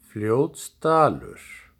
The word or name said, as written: Fljótsdalur